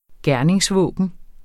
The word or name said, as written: gerningsvåben